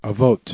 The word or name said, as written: avot